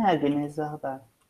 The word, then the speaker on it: Agnès Varda: É Agnes Varda.